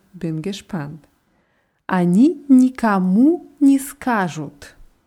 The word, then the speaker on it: скажут: Скажут. Die Betonung ist auf dem A.